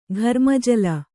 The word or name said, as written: ಘರ್ಮಜಲ